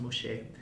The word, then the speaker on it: muxes: But like, the Moshe people, it's spelled M-U-X-E-S, but it's pronounced Moshe, are a third gender identity in connection to Zapotec culture in Oaxaca, in Mexico, right? – Mm-hmm.